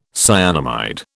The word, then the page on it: cyanamide: British English